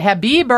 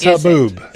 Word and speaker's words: haboob: Is it Habib or Haboob? What it's Haboob. – It's Haboob. – Haboob. And you have to say it like that.